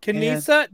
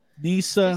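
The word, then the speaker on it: Kneesa: Kanisa? No, the K is silent. – Nisa. – It's Nisa.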